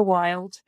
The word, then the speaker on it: Wylde: It's just my full name, which is Ruth Roper Wilde. And the Wild is spelt odd. It's WYLDE.